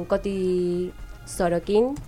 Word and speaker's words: Coti Sorokin: Eh, que es con, un fit con Coti Sorokin, sí.